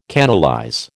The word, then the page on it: canalize: American English